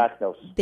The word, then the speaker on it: anthracnose: She's wondering about anthracnose, is that, am I pronouncing? – Anthracnose. – Thank you.